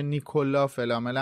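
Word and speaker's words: Nicolas Flamel: خب میرسیم به بحث نیکولاس فلامل که اسم این فصل هم هست و میشه نیکولا فلامل هم تلفظش کرد از اونجایی که شخصیت فرانسوی هستش.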